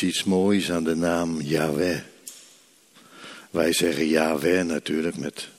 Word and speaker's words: Yahweh: Als je inademt is het. En als je uitademt. Dus met je in- en uitademen spreek je die naam Yahweh uit.